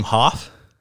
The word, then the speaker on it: Hoff: First, you mispronounced your own last name. You said Huff. – Oh. What? How do you pronounce it? – Isn't your last name Hoff? – Yeah, you're right.